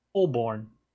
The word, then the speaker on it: Holborn: Holborn. – Holborn. – Why? You're just adding extra letter. Like, you add the extra letter and like, nope, you don't pronounce that extra letter.